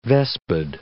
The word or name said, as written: vespid